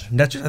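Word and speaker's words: Nečas: Necas.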